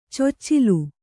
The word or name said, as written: ಚೊಚ್ಚಿಲು